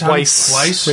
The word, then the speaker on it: Weisz: Is that Wise? – Weiss. Rachel Weisz.